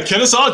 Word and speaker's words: Kennesaw: Is that Kennesaw, Georgia? – Yeah, Kennesaw, Georgia.